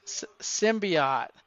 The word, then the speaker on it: symbiote: God, I can't say it right now that they've been symbiote. I get confused now, Barb, because we saw the preview and they mispronounce it. Now I'm mispronouncing it. Symbiote.